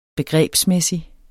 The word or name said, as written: begrebsmæssig